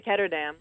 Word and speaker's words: Ketterdam: Of course, it takes place in a city called Ketterdam, based on we know, of course, Amsterdam, and I think, do you pronounce it Ketterdam? – I just say Ketterdam.